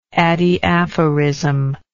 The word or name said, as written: adiaphorism